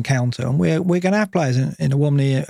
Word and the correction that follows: Awoniyi: a Womney. Oh, sorry, I can't pronounce his name. I've got to learn to say Awoniyi and Johnson, who have got pace and they can hurt defences.